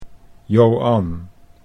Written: Joan

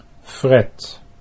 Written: Fred

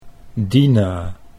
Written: Dinah

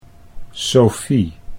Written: Sofie

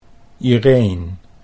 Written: Ireen

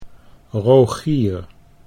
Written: Rogier

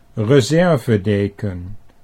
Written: reservedeken

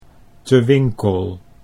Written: Te Winkel

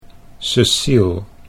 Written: Ceciel

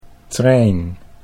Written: Trijn

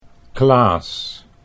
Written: Klaas

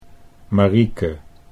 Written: Marieke

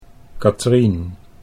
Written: Katrien

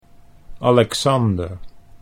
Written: Alexander

